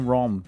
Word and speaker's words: ROM: O nome do programa era Nero Burning Rom, sendo que Rom tem a mesma pronúncia de Rome.